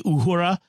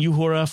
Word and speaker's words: Uhura: Someone mentioned the way I pronounce Uhura. And pointed out that in the actual Swahili would be Uhura. And I grant that. The fact is, I've been saying Uhura for nearly five decades.